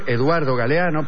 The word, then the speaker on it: Eduardo Galeano: También del mismo autor, Eduardo Galeano, pronunciado ahora con mucha más corrección, Bocas del Tiempo.